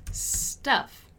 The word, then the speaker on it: stuff: And the pronunciation is stuff, right? Stuff, stuff, stuff.